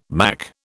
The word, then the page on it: Mac: British English